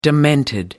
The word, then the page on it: demented: American English